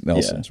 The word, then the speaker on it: Nielsen's: Their founder is going to be right outside of Nielsen's. Is it Nielsen's or Nelson's? I've lived here all of my life. It's spelled Nielsen's, but I think it's Nelson's.